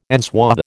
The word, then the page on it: enswathe: American English